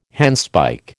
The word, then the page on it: handspike: American English